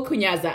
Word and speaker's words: Kunyaza: Google Kunyaza. I will spell it for you. – Yes. – K-U-N-Y-A-Z-A.